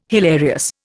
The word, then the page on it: hilarious: Indian